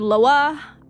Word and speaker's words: Loa: It's just L-O-A is how it's pronounced. – Oh, okay. – I've heard Loa. I've heard Lawa. – Okay. – I think it's Loa. – Okay. Let's go with that. That seems easy enough. – Yeah. Which each Loa is responsible for different domains, very much like Greek or Roman gods and goddesses.